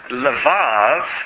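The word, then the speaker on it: levav (לבב): In fact, that word levav is spelled in Hebrew, lamed, beit, beit. Sometimes it's just shortened to the word lev for heart. But the full spelling is levav.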